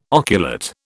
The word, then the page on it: oculate: British English